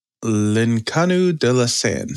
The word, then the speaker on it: L'Inconnue de la Seine: But to clarify, um, we're talking about L'Inconnue de la Seine. Is that how you say it, Brandy? – Yeah, good job.